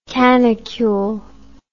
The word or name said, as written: canicule